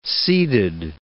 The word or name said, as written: seeded